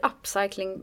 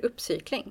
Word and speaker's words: upcycling: Säger du upcycling på det engelska med det engelska uttalet eller säger du uppcykling?